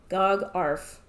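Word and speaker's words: Gagharv: So, the next games in the series are part of the Gagarf sub-series, and if you'd want to know how that's spelled, it's G-A-G-H-A-R-V, Gagarf, whatever that means.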